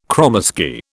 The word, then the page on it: kromesky: British English